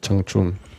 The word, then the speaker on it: Changchun: Ich habe zuerst mal äh, Chung gesagt, aber es ist Changchung.